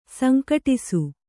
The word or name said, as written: ಸಂಘಟ್ಟಿಸು